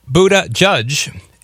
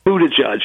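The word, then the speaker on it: Buttigieg: but apparently Buddha Judge is how it's pronounced, and that's why I would... – Buddha Judge, yes.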